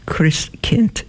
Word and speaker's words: Christkind: Christkind under mother's watchful eye, and that's the Christ child, Christkind, it's pronounced.